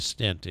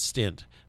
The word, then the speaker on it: stent: But I did have some blockage that they had to put a stent in for. And I used to pronounce it stint, but it's not a stint. It's a stent. I know. E, not an I.